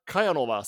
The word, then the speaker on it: Kajanova: Eh, Sara Kajanova, säger man så? Ja, det är, det är fläckfritt.